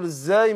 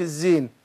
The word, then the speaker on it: الزاي: وطبعا قلنا بننطق بنقول الزاي مش الزين ماشي. يعني بعض الناس طبعا مشهور عند كثير من الناس يقول حرف الايه حرف الزين. حرف الزين ده اسم واحد واخد بالك.